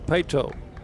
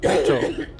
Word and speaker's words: Paetow: Two balls and a strike to Pato. That, by the way, is spelled P-A-E-T-O-W. Carson Pato.